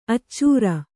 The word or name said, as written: ಅಚ್ಚರಿ